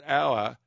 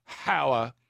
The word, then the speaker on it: hour: Moira says, Nick, our is pronounced our, hower, H-O-U-R.